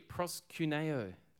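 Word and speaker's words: proskuneo (προσκυνέω): It says here, proskuneo. That's how you pronounce this word.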